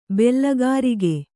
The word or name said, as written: ಬಿಲ್ಗಾರಿಕೆ